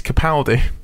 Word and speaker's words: Capaldi: But I heard him on another station intro his own song, and he's Capaldi. That's how he pronounces it.